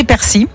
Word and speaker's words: persil: Alors normalement c'est persil, ok, ouais. D'après le dictionnaire de l'Académie française, mais le nord et le sud du pays prononcent quand même le L, donc t'as le droit quand même aux deux.